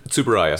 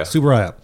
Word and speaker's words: Tsuburaya: Eiji Tsuburaya. – Tsuburaya.